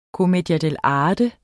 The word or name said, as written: commedia dell'arte